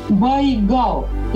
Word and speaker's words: Байгал: И, естественно, это слово Байкал, оно трансформировалось в бурят-монгольское произношение как Байгал.